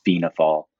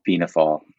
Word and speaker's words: Fianna Fáil: There are a couple pronunciations of the political party. The easiest way for non-Irish speakers is Fianna Fáil. – Fianna Fáil? – Fianna Fáil.